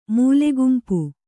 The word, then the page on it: ಮೂಲೆಗುಂಪು: Kannada